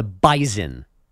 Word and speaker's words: Bison: Why do you keep saying S's as Z's today? – Well, that one in particular, that's how they say it. They are not the Bison. They are the Bison. – What is a Bison? – It's the same thing, but you really got to stress it.